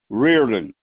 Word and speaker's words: Riordan: No, Reardon. Last name is spelled Riordan.